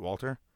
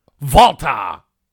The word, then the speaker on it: Walter: I'm sorry. I didn't say that right. Did I say it right? Walter? – Volta!